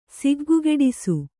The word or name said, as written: ಸಿಗ್ಗುಗೆಡಿಸು